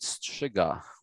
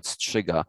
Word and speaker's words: strzyga: In Polish, you would call it strzyga, which I know it's a very hard to pronounce for foreigners, but in Polish, we call it strzyga.